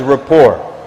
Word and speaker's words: rapport: So when it comes then to communicating, sometimes there's something which is really nice for and beneficial for us to know, known as rapport. Rapport. R-A-P-P-O-R-T. Rapport. And rapport basically is, that's really how it's spelled, by the way.